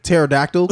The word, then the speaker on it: pterodactyl: I can understand if my shit was spelled like pterodactyl. Because have you seen the P in that bitch?